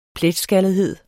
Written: pletskaldethed

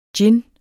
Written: GINNERNES

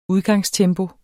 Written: udgangstempo

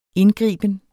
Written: indgriben

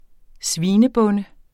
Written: svinebonde

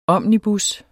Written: omnibus

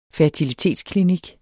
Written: FERTILITETSKLINIKKEN

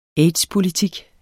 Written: aids

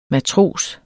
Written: MATROSERS

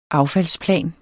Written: affaldsplan

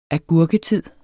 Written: agurketid

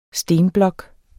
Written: stenblok

